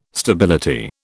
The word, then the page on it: stability: British English